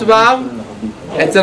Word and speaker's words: ו: הלאה, האות וו, אצל רוב הספרדים, כמעט כל הספרדים ואצל התימנים, מבטאים אותה בדיוק כמו W אנגלית, ווא, ולא כמו האשכנזים שמבטאים אותה כמו האות V האנגלית. אגב,